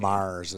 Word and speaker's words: Maris: Mars is how you pronounce that name. – Really? – Mm-hmm. – It's a silent A, a silent Mars.